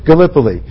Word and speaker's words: Gallipoli: Gallipoli. Okay, Gallipoli.